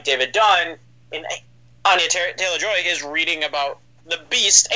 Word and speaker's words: Anya Taylor-Joy: Anya Taylor-Joy. Is that how you pronounce her? – Yes. Yeah.